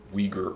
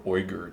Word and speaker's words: Uyghur: or Uyghur or Uyghur, depending on your pronunciation scheme for that language.